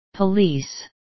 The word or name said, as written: police